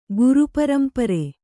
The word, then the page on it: ಗುರುಪರಂಪರೆ: Kannada